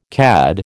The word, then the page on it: CAD: American English